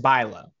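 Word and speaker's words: Bülow: It's spelled B-U-L-O-W, but like the U has the two dots over top of it. – Oh, yes. I know who this is. Yes. – Oh, okay. Yeah. I know who that is. Got it. – Pronounced Bilo.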